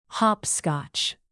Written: hopscotch

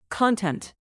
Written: content

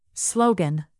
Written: slogan

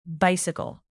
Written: bicycle

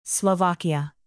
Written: slovakia

